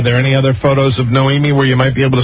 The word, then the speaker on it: Noemie: So listen, no, how do you even pronounce this name? N-O-E-M-I-E? – Noemi? – Noemi Leno.